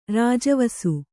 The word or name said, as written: ರಾಜವಸು